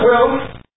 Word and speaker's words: Aquila: supposed to be pronounced Aquila, you know that.